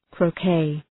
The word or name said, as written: croquet